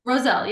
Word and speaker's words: Rossell: So the single was co-written by and produced by Julia Poorman and Danny Rossell. Did I say it right? – Rossell, yep.